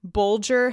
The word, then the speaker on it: Bulger: He says, for example, Bulger has a G as in bulge, and Matham rhymes with fathom.